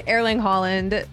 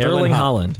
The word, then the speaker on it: Haaland: Okay, Tom Holland DM'd Erling Haaland. – Yes. Erling Haaland. – No, you're making it harder than it is. Erling Haaland.